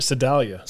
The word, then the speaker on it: Sedalia: Well, without giving too much away too soon, DK is from Salida. Is that how you say it? Sedalia.